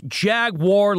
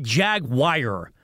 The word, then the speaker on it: Jaguar: It's Jaguar, like Star Wars, right? Not Jag-wire.